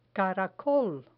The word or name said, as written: caracol